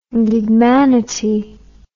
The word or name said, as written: gigmanity